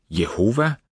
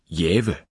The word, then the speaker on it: Jehova: På dansk udtales navnet almindeligvis Jehova eller Jave.